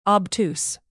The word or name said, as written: obtuse